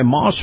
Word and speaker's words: Maus: Moss Family Automotive and Moss Nissan. Yep, Moss Nissan. Moss is spelled M-A-U-S.